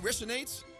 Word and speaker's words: Resonate: Nou, bij de Speeltoren Resonate. Spreek je het zo uit? – Ja, ja, Jennifer Kira en Moerad.